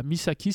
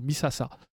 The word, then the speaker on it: Misasa: En fait, c'était pas Misaki, c'était Misasa. Euh, ça s'écrit euh, avec euh, deux caractères qui sont 3 et Matin. C'est la ville des Trois Matins, en gros. Et puis, ils avaient lu ça Misaki au lieu de Misasa.